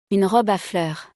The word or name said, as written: Une robe a fleurs